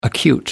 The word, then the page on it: acute: American English